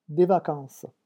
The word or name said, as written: des vacances